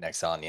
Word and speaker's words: Nexon: is it Nexon? Did I say that right? – Yep, Nexon, yeah.